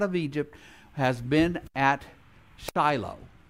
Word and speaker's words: Shiloh: You and I would pronounce it Shiloh. If you go to Israel, they'll call it Shiloh.